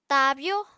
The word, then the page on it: taabyó: Apatani